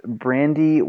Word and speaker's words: Brandi: And we're back, guys, with Brandi Wan from IN3. I'm pronouncing that, Brandi. Is that correct? – That's correct.